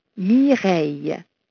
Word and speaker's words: Mireille: If you speak a little French, my name rhymes with soleil, Mireille.